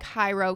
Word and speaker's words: Jairo: And I looked it up for pronunciation, and that's how it told me to say it. it looks like Hiro.